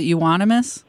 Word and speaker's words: Euonymus: A Eunice? Is that what you pronounce it? – Is it Euonymus? – Yeah, right.